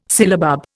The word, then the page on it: sillabub: Indian